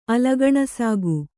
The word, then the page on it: ಅಲಗಣಸಾಗು: Kannada